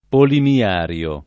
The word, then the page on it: polimiario: lingua italiana